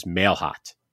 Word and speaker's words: Mailhot: It is Mailhot. M-A-I-L-H-O-T, Jake Mailhot.